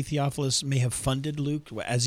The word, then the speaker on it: Theophilus: Well, one thing off the top is that with this, how do you say it? Theophilus? – Theophilus, yeah.